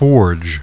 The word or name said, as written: forge